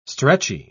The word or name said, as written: stretchy